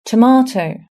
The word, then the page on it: tomato: British English